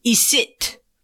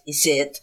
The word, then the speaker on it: icitte: Isit. You're not going to guess. – Spell it. – I-C-I-T-T-E. It's kind of like fret. Isit.